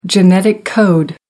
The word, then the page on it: genetic code: American English